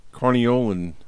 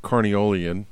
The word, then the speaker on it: Carniolan: So one of the other running stories in our yard is about the Carniolan hive. Not Carniolian, as I've been saying in the last episode. Bob reminded me that's not how you say it.